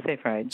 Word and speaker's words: Seyfried: Colin Campbell, who's probably known to many listeners of this podcast, and the other is, I'm not sure how to pronounce his last name, Seyfried or Seyfried? – Yes, Seyfried.